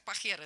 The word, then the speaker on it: Pajero: Название для машины, А-а-а. Которая не едет. Или Mitsubishi Pajero. Оно читается как Pajero. Это неприличное А-а-а.